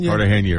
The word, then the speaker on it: Cartagena: Cartagena, right, yeah.